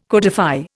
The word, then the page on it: codify: Indian